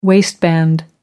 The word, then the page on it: waistband: American English